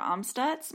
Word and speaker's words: Amstutz: You can find me on all social media platforms at Sandra Amstutz. My last name is spelled A-M-S-T-U-T-Z.